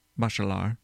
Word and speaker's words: Bachelard: Bachelard. I don't know if you pronounce the D. – Sounds pretty good.